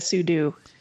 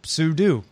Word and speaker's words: sudo: But the co-inventor, Robert Cogshall, says it's meant for super user do. You should say sudo. So in this case, Axon ruled sudo. Sudo is the proper pronunciation. We've just all been saying it wrong.